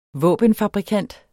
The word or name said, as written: våbenfabrikant